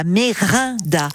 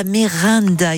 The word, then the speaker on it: Merenda: J'ai mal prononcé. C'est la Merinda, La Merinda, effectivement, parce que le e EN en Nissar, comme en provençal, se dit un.